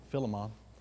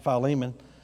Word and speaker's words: Philemon: I was taught as a kid, pronounced it Philemon. Then I get to Bible college and they pronounced it Philemon. And then I found out later on that you can pronounce it both ways. Neither one is wrong.